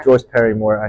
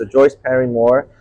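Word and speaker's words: Parry-Moore: Joyce Parrymore. Actually, I keep trying to remember, but okay, so Joyce Parrymore, yeah.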